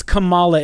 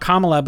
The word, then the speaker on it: Kamala: It's Kamala. It's not Kamala, because Kamala is Kamala Harris.